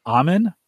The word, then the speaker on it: Amun: A-M-U-N? – Amun? – Amun? – Amun, because that's basically where Tutankhamun got his name from, because they grabbed it from, oh, well, he's a pharaoh, so he's closest to the god.